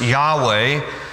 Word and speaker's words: Yahweh: It is often pronounced Yahweh.